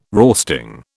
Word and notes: roasting: British English

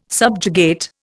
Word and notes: subjugate: Indian

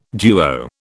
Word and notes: duo: British English

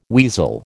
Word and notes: weasel: American English

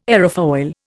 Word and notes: aerofoil: Indian

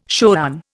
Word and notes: shoran: Indian

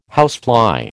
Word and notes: housefly: American English

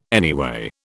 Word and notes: anyway: British English

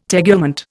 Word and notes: tegument: Indian

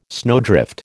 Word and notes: snowdrift: American English

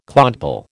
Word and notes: clodpoll: American English

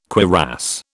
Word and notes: cuirass: British English

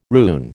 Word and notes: rune: American English

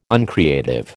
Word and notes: uncreative: American English

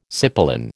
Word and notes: cipolin: American English

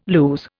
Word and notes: lose: Indian

